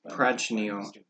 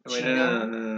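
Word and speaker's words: Prachnio: Oh no, Prochino. Prochino. That's how you say it. – I called it Prochino. – Prachino. No. I'm sorry. – Prochino. – It's not Chino. It's Chnio.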